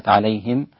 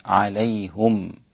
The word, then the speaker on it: عليهم: سواء عليهم، عليهم كلمة عليهم حمزة ويعقوب بضم الهاء، فيصير النطق بها هكذا عليهم، عليهم، نعم.